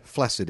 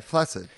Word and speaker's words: flaccid: Because my pronunciation has always rhymed it with acid. Would you be the same? – Yeah, flaccid, yep. – Turns out the correct pronunciation is flaccid.